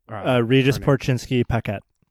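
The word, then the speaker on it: Regis Korchinski-Paquet: I always struggle to pronounce the person from Toronto. – Regis Porcinski Paquette. – Thank you.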